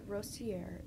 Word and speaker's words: Rostier: Like Rostier, if you're going to be American.